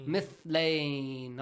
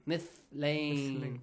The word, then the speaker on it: مثلين: مثلين. – Not مثلين. مثلين. Pronounce it correctly.